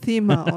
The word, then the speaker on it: Thema: Nee, nee, du musst es, TH spricht man Thema aus.